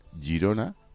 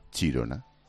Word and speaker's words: Girona: Bueno, pues enviar a Chirona era la pronunciación cateta de enviar a Gerona, que era aquella cárcel. Girona, Chirona.